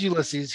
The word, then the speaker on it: Ulysses: He goes, I couldn't even pronounce his name. And I was like, yeah, it's Ulysses.